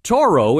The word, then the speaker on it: Toro: But since folks still recalled the old Bull Tractor Company, they'd often mispronounce the name Two Row, calling it Toro instead.